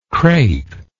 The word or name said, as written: crapy